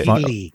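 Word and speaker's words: Keleigh: It's K E L E I G H. How would I pronounce? I want to say Kelly. – Yeah, Kelly.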